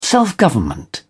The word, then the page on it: self-government: British English